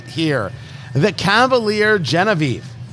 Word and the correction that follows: Genève: Or is it Genève? Not Genevieve, it's Genève. I pronounced it wrong.